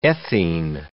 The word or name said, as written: ethene